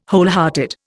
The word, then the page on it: wholehearted: Indian